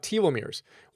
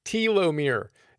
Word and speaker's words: telomere: Telomere is pronounced, as I keep on saying it, telomere, not telomere. Well, telomerase is pronounced telomerase. A little weird there, but there you go. Telomere. If you're like me, you used to say telomere. No more.